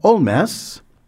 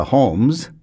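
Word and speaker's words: Holmès: She was born Augusta Holmes, of Irish parents, but when she became a French citizen officially, she added an accent grave to her last name and started pronouncing it as if it were French.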